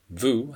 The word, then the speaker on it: vous: Vous has the OU sound, like in boot.